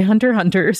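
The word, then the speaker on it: Hunter × Hunter: And so also, I did learn recently that if there's an X, it's usually not pronounced. So like Hunter X Hunter is actually Hunter Hunter.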